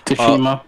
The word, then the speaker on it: Tsushima: Tsushima. – Tsushima, yeah. Ghost of Tsushima. Ghost of Tsushima. There we go. Which one would you pick? Me. – Last of Us. – I picked Ghost of Tsushima.